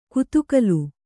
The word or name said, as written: ಗುದಿಗಾಲು